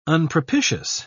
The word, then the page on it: unpropitious: British English